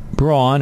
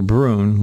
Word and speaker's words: Bruen: Roughly 2 weeks later, it was pointed out in the Washington Times, suggesting the Braun or Bruen, whichever way you want to pronounce it, it's B-R-U-E-N, decision, puts all types of gun control into crosshairs of gun rights groups.